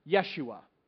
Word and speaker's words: Yeshua: Here's how you say it in Hebrew, Yeshua. Here's how you say it in Greek, Jesus.